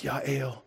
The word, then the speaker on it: Jael: Jael. That's Yael.